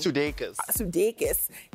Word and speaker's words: Sudeikis: I can't say his name. – Sudeikis have ended their 9-year relationship.